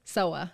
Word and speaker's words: Sowa: Sowa.